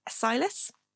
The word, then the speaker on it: cilice: And so Paul Bettany's character is called Silas, and also the garter belt thing that he's wearing is also called Silas. Or it's spelled C-I-L-I-C-E. It's also known as a sackcloth,